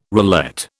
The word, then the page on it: roulette: British English